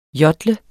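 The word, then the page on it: JODLER: Danish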